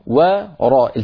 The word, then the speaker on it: وراء: أيضا في الآية نفسها: وأحل لكم ما وراء ذلكم، وراء التقى في النطق واو مرققة وراء مفخمة، فعلى القارئ أن لا يفخم الواو بسبب مجاورتها للراء، يعني ما يقول من وراء وراء، العرب لا تقول وا وإنما يقولون و و و وي.